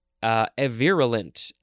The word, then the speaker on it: avirulent: All right, next we have avirulent with a long A, A-V-I-R-U-L-E-N-T.